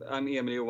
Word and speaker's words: Emil: I'm Emil Johansson. – First name is spelled, hey, for the American audience, E-M-I-L, dummies, Johansson.